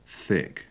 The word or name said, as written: thick